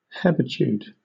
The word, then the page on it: hebetude: British English